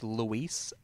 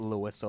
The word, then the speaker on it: Luis: And um, also I when um, his name is pronounced Luis and not Lewis or Louis.